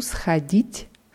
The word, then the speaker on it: сходить: Я хожу сходит в кафе. – Я хочу сходить в кафе. Die Betonung nicht vernachlässigen. Sag es mal nochmal. – Я хожу сходить в кафе.